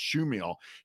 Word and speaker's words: Chmiel: Fred Schumiel, spelled C-H-M-I-E-L, but it's pronounced Schumiel.